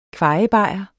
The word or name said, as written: kvajebajer